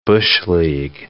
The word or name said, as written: bush-league